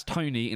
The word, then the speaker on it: Torgny: It's T-O-R-G-N-E-Y. That's Tony in a Scottish accent. Torgny, isn't it? His name's Tony. You can say Torgny as much as you want. It's Tony.